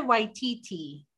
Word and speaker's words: Waititi: It's just pronounce it Waititi.